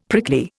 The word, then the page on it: prickly: Indian